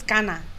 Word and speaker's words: Ghana: If you say it with a Ghanaian accent, I feel like it's Ghana. Like, so when I say Ghana, I say I'm Ghanaian. Like, I don't say I'm Ghana.